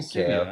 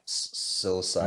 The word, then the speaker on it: psilocybin: Why are you saying Sibian? I said it's Sibian. – Psilocybin.